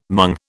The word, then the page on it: mung: British English